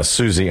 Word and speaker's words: Siouxsie: Susie is spelled S-I-O-U-X-S-I-E.